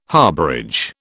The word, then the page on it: harbourage: British English